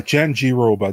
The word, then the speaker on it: Jandiroba: We got Vina Jandiroba. Is that how you pronounce it? – Just Jandiroba. Jenny Roba. Jen, calls it Jen G. Dover, right? – Jen G. Roba. Jen G. Roba. That's it.